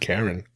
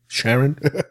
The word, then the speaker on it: Charon: Now, the last figure on the line is Charon, I think that's how it's pronounced. Sharon, Sharon, Karen, not Karen, Sharon.